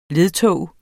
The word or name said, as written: ledtog